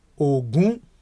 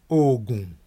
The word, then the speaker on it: Ogun: You can take one word to mean five different things depending on the way you pronounce it. If you take the word O, G, or G, we say, U, N, you know, that's Ogun, Ogun. Ogun. Ogun. Ogun. Ogun, the god of iron. Ogun, war. Ogun, medicine. Ogun, sweat. Ogun, 20.